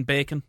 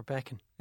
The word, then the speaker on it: Bekan: It's in Bacon. – Where is Bacon? – Bacon's just outside Ballyhonus. – Spelt B-A-C-O-N. – B-E-K-A-N. – Spell it again? – B-E-K-A-N. – Bacon.